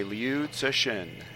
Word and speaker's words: Liu Cixin: So, hey, guys, this time we will be discussing The Three-Body Problem by Liu Cixin.